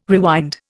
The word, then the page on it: rewind: Indian